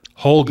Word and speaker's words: Holga: Holga.